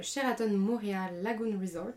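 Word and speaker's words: Sheraton Moorea Lagoon Resort: mais également le Sheraton Montréal Lagoon Resort. Je ne sais pas si je le dis bien, en Polynésie Tu le dis bien, c'est ça.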